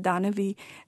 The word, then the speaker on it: Danavi: Danavi, which rhymes with Manavi, means monsters.